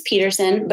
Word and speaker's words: Pedersen: So my username on TikTok is the Mrs. Peterson, but it's all E's and a D.